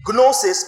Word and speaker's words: gnosis: The word gnosis, the G is silent. Gnosis, but pronounced gnosis, is a word that depicts experiential knowledge of.